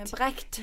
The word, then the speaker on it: Brecht: I don't know how you say it in German. He's a German theatre practitioner. – Brecht.